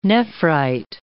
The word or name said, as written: nephrite